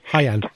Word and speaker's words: Haiyan: So that would include something like the typhoon, I don't know if I can pronounce it correctly, Haiyan. – Haiyan. Typhoon Haiyan, yes, that's right.